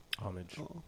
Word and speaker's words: homage: How do you say that again? – Homage.